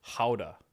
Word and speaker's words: Gouda: Gouda. – Yes. – Is that how they pronounce it? – Apparently. – They pronounce their G's like H's.